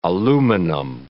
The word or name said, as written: aluminum